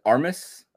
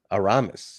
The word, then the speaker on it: Aramis: Is it Armas? – Aramis. See, you need more Chipotle.